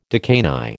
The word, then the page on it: decani: American English